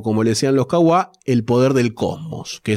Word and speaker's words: Kwa: Entonces los Kawa, vamos a decirle Kawa, pero se escribe KWA, se expandieron eh, durante miles de años.